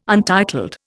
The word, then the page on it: untitled: Indian